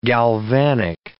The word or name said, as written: galvanic